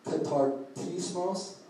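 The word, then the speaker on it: katartismos (καταρτισμός): And it's pronounced katartismos.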